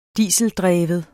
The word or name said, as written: dieseldreven